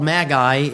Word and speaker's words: Magi: They're called Magi, M-A-G-I, or Magi, depending on how you want to pronounce that in secular writings.